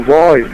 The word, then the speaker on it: Roy: Name's Roy. – Can you spell that? – You want me to spell Roy? Really? R-O-Y, Roy. – Oh, Roy. R-O-Y.